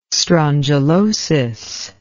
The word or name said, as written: strongylosis